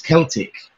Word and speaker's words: Celtic: But, it's kind of open to interpretation because, even now we've got the word Celtic, but you can also pronounce it Celtic. We've got a football team in Scotland called Celtic. – And we have the Boston Celtics out here, yeah. – Yeah. Yeah, so you can kind of choose, really, which one you prefer, the hard K or the hard C, which is pronounced as a K, or the soft C, which is pronounced as an S.